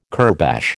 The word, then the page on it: kurbash: American English